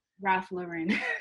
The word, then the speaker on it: Ralph Lauren: Now, you also was like an intern for Ralph Lauren at, or is it Ralph Lauren or is it Ralph Lauren? How you pronounce it? – Ralph Lauren. – Excuse me. Ralph Lauren.